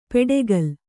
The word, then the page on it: ಪೆಡೆಗಲ್: peḍegal